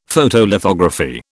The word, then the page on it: Photolithography: British English